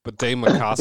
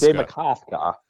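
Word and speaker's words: Bde Maka Ska: Bidet Makaska. – Bidet Makaska.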